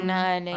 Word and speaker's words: عليهم: عليهم the pronunciation of عين. Be careful, okay? عين عليهم, not عليهم. عليهم maybe it has another meaning because it's همزة. عليهم. It's not like Persian or something, yeah?